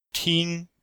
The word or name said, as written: ting1